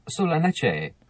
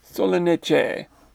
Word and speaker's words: Solanaceae: Actually, let's get our Google friend to pronounce that. Here we go. – Solanaceae. – Solanaceae. We'll go with that.